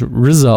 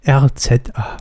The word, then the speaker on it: RZA: RCA. Ich glaube, er will wirklich RZA ausgesprochen werden. – RZA.